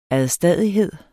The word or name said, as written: ADSTADIGHED